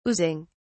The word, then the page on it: oozing: British English